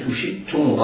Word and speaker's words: شئتم: فكلوا منها حيث شئتم رغدا، شئتم رغدا، احرص على بيان كسرة الشين حيث شئتم. بعض الناس ينطقها يعني كسرة أو الشين مخفية المعالم، لا نعرف حركتها، هي كسرة هي ضمة؟ لا، انتبه لذلك جيدا، حيث شئتم. أوهمني وأنت تنطق الشين المكسورة، أوهمني أنك ستنطق بعدها ياء المادية، أوهمني بذلك، لكن لا تفعل، لا تنطق بالميم الياء المادية.